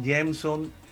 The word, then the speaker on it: Jameson: El nombre que me dio mi madre realmente se pronuncia en inglés, Jameson Ramírez, escribe Jameson uh-huh.